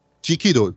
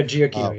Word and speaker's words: Giacchino: And last, but certainly not least, Michael Giacchino's score, if I'm butchering the surname, sorry. – Giacchino, right? – Yeah, Giacchino, yeah.